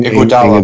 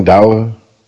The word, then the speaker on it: Iguodala: Ing- Iguodala. Iguodala.